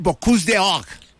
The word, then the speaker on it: Bocuse d'Or: ¿Qué es el Bocuse d'Or? Explíquele. No, no sé si lo dije bien en francés. Mi francés está, no es de Marsella. – Está perfecto, está ahora, perfecto.